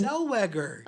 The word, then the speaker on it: Zellweger: Renee Zegwellar? – See, you can't even pronounce her name. You don't know where she... – How do you say her name? – Zellweger. – Oh, Zellweger. Oops.